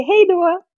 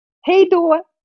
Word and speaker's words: hej då: That's Italian. You say hej då. – Hej då. – Hej då. – Hej då. – Hej då. – Hej då. – Hej då. Hej då.